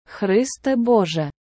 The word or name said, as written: Христе Боже